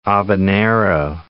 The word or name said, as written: Habanero